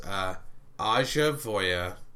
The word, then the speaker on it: AishaVoya: Aja Voya has sponsored this episode, so be sure to check out Aja Voya. And it doesn't sound like you think it's spelled. There's no G in there.